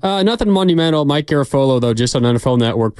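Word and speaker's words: Garafolo: It doesn't rhyme with Garoppolo. It's Garofolo.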